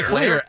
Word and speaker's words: Fleer: I never heard one person say Fleer. How do you say it? Like Fleer. Fleer. F-L-E-E-R. – I know how to spell it. Flair. – Fleer. – How do you get... – I know it's conventionally, conventionally F-L-A-I-R or even an H if you want to go German in there.